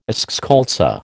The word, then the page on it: eschscholtzia: American English